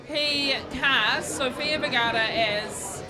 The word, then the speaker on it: Vergara: Good on you for the correct pronunciation of Vergara. Sofia Vergara.